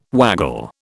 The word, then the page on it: waggle: British English